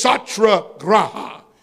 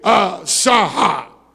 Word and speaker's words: satyagraha: And that word is satyagraha. Go on and talk that Indian language and say satyagraha.